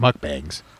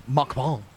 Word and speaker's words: mukbang: take it easy with the hard K. It's mukbang. – I thought it was mukbang. – Mukbang. – Mukbang? – Yeah. – That is not what it's called. – Oh, it's a hard G.